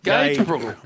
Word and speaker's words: Gagebrook: Gagbrook. – Yes. – Yes. Is that how you say it? – Gagebrook. – Really? – Okay.